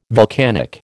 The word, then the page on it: vulcanic: American English